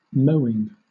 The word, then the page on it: mowing: British English